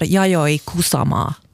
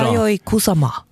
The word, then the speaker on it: Yayoi Kusama: Vet du för det första, det är inget quiz, men hur uttalar man hennes namn? – Jag måste ju nog kolla upp det där. Yayoi Kusama. – Ja, bra. – Yayoi Kusama. – Ja, det låter bra.